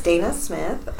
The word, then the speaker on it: Dana Smith: My name is Dana Smith.